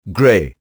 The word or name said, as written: grey